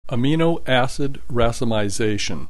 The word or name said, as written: amino acid racemization